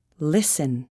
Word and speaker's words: listen: The letter T is not pronounced in these common words. Castle, Christmas, listen, whistle.